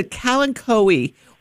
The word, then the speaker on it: Kalanchoe: But if you've got a lot of room and you want to plant them, then it's a Kalanchoe or a Kalancho, however you want to say it. Both pronunciations are correct.